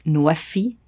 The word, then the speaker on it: NWEFI: It's pronounced in Arabic, NWEFI.